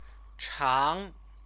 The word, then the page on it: chang: chinese